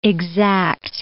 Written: exact

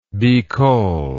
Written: bicol

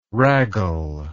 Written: raggle

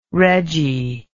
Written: reggy

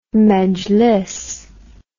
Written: mejlis

